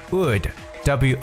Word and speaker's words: would: cool 啊，就是把 l 放到最后边，但是呢，l 在有一些情况也不发音，特别大家常见的这三个词，一个是 could，c o u l d，could，我们发的是长 u，could，而还有 should，以及 would，w o u l d，所以这个 l d 结尾呢，这个 l 呢也是不发音的，could，should，would。